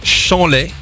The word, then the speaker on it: Champlay: On est à côté d'Auxerre, dans l'Yonne, dans une ville qui s'appelle Chanlay. Mm-hmm. Alors que ça s'écrit Champlay. D'accord. – Ah, ça se dit Chanlay. – C'est ce qu'on appelle un P discret.